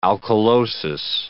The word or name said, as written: alkalosis